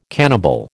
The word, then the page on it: cannibal: American English